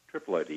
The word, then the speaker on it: Triploidy: It's called Triploidy. Is that how you pronounce it? – Triploidy, yeah.